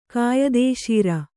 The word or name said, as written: ಕಾಯದೇಶೀರ